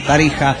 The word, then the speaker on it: Tarija: Y creo que con el tiempo resumieron el nombre y lo llamaron Tarixa, ¿no? Tarija. Que al igual que México, la letra X se pronuncia como J.